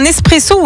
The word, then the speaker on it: espresso: Alors, tiens, est-ce que tu dis un espresso ou un expresso? – Pour le café? – Oui. Ouais. – Espresso. – Espresso, ouais. Étymologiquement, on ne prononce pas le X.